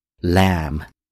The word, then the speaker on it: lamb: 羊肉呢叫做 mutton, 那 mutton 指的是成年的羊的羊肉，当我们指小羊或者羔羊肉的时候呢我们叫 lamb, 就后面这样的一个单词 lamb, 但是呢最后一个字母 b 是不发音的，所以呢读作 lamb,